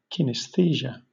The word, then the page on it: kinesthesia: British English